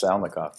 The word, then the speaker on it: Salnikov: And it's about Vladimir Salnikov. Am I saying that right? – Yes, Salnikov, yeah.